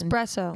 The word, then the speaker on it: espresso: Espresso. – And, am I saying it wrong with an X?